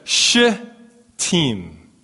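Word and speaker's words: Shittim: Uh, but then I looked up the Hebrew pronunciation, and it's more like a sh team. There's a, there's a gap there, and I'm going to really pronunciate the gap. You got to say it. Sh team.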